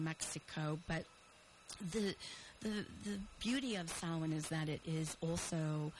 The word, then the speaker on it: Samhain: And there's a lot of different pronunciations of that, so in talking with people, everyone kind of has their own twist on it, but I say Samhain, so that's where we're at today.